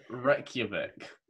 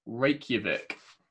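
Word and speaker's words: Reykjavík: No, your answer is Reykjavik. – Ah. – R-E-C-K-U-V-I-C. – Wrong. Wrong. – Can you pronounce it for me again? – Reykjavik. – R-A. – Incorrect. – Is it R-A-K? – No, it's R-E-Y-K.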